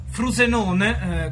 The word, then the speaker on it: Frosinone: Frosinone, o meglio Frusenone, come si pronuncia nella loro lingua, nel loro idioma,